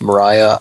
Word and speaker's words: Maria: I think it was just pronounced Mariah.